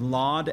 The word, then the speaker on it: Lod: The city of Lydda is also the city of Lod. L-O-D is the modern day spelling and pronunciation of it.